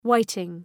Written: whiting

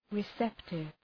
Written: receptive

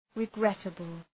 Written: regrettable

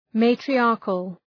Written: matriarchal

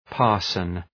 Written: parson

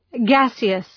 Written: gaseous

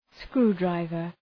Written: screwdriver

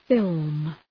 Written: film

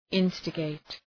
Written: instigate

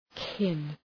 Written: kin

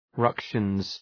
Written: ructions

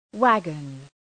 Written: waggon

